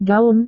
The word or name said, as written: gaun